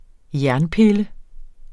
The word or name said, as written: jernpille